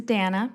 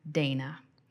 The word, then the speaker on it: Dannah: My name is Dana, pronounced like Hannah, not Dana.